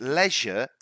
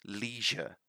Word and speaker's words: leisure: But you need to pay attention to this one because leisure is the British pronunciation. American pronunciation would be leisure.